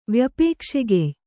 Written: ವ್ಯಪೇಕ್ಷೆಗೆಯ್

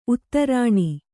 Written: ಉತ್ತರಣೆ

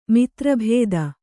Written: ಮಿತ್ರಭೇದ

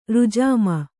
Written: ರುಜಾಮ

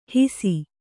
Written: ಹಾಸು

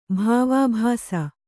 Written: ಭಾವಾಭಾಸ

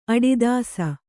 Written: ಅಡಿದಾಸ

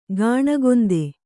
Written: ಗಾಣಗೊಂದೆ

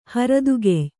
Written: ಹರದುಗೆಯ್

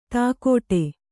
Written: ಧಕ್ಕಡಿ

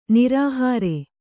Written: ನಿರಾಹಾರ

